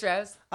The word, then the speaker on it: rose: Again, this Rose. – Another person, that Tayshia, the way she says Rose, she does not pronounce the Z.